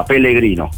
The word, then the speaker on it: Pellegrino: Sì, perché no, qualcuno mi prende in giro perché io chiamo l'attaccante del Parma accostato alla Fiorentina Pecegrino. Si dice Pecegrino? – No, ecco, lì per il cognome italiano, quindi lo sbagliato. Loro lo pronunciano con una L sola, Pellegrino.